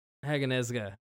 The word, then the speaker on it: Haganezuka: It's Hagun Izuka. Zuka. Hagun Zuka. Yeah, here. I have his name. It's there. – Okay, where is it? Haganezuka? – Haganezuka, yeah. It's very quick pronunciation, I think.